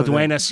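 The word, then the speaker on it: Dueñas: Yeah, Duenas, maybe, because there's that little squiggly thing. – Lola Duenas.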